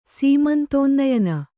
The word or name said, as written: ಸೀಮಂತೋನ್ನಯನ